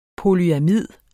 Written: polyamid

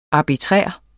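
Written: ARBITRÆRT

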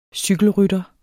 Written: CYKELRYTTERE

